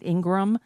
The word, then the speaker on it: Ingraham: I know she pronounces it Ingram, but it's spelled Ingraham. And since I've never actually heard her say her name, I'll pronounce it the way it's spelled. Laura Ingraham.